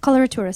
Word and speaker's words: coloratura: Coloratura soprano. – Oh, man, I got to write this down. – Mm-hmm. – Cholera. – Yeah, coloratura. – Coloratura. – That's right.